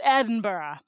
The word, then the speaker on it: Edinburgh: It is not like Pittsburgh. It's Edinburgh.